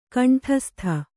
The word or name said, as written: ಗಂಡಾಸ್ಥಿ